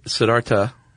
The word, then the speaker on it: Siddhartha: Actually, Siddhartha. – Okay, so no, the H is silent.